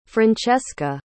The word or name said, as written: francessca